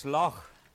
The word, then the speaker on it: Lough: We've been called various things on our travels, not only here but in England, because they don't seem to be able to pronounce that word, L-O-U-G-H. It's Loch,